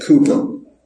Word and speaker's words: Cowper: Now, his name is spelled C-O-W-P-E-R, and I called him William Cowper for many years. Found out later they said Cooper.